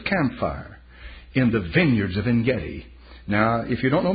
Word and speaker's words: Ein-Gedi: As a matter of fact, at En-Gedi, Ein-Gedi, Ein in Hebrew is the spring. That's what the word means. A correct pronunciation will be Ein-Gedi.